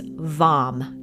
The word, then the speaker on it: VAM: And the sound seed syllable is pronounced like the word lawn. So it's VAM, not VAN.